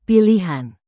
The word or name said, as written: pilihan